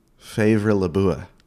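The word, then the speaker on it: Favre Leuba: Favre Labua. – Favre Luba? – Yeah, exactly.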